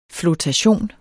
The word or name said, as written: flotation